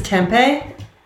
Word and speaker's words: tempeh: I think maybe probably one that people aren't familiar with is tempeh. And that's how you say it. It's spelled different than it sounds. So tempeh, I kind of go back and forth.